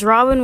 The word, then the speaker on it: Robiné: My name is Robin with an E and an accent mark, but the E is silent.